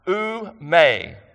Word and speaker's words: ou me: Oume, that's how I pronounce it, O-U-M-E.